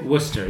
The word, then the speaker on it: Worcester: Worcester, yeah. Worcester, yeah. Worcester pronounced the same way you do in England, I guess. But, not the sauce, no.